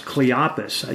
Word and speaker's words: Cleopas: And these two men, well, we don't know if they're two men, but that's Cleopas, how do you pronounce that? – Caiaphas? – No, it's Cleopas, I think. – That sounds right.